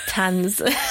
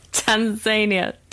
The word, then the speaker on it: Tanzania: It could be called finding Tanz. I always say it wrong. Tanzania.